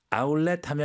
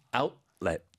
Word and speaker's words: 아웃렛: 아울렛 하면 이해는 할 것 같은데 네. 발음이 조금 틀리죠. 아웃렛.